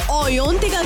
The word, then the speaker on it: Ayantika: मुझे ओयोंतिका की दुनिया का हिस्सा बनना है तो कम से हाय to me on my social media handles.